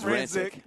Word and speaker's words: Rancic: Did I say her name right? – I think it's Rancic. – But Rancic. Whatever. – I think it's Rancic, Brad.